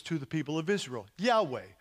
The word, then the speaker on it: Yahweh: Jehovah doesn't really work. It only works because for German people, they pronounce you're here this morning to check my German, but Y's are pronounced like, or J's are pronounced like Y's, Jan, right? Something like that. And so, but Jehovah's not right, but Yahweh probably is a whole lot closer.